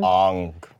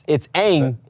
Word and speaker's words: Aang: And just little weird things, like he changed the pronunciation of the main character's name. – Aang. – Like, it's Aang. It's like literally said Aang in the TV show for forever, and then he's like, Aang, and asked why he said it was more, like, culturally correct. I'm like, they said how to pronounce it in the TV show. You don't change it if they've said how you do it.